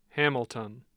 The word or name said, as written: Hamilton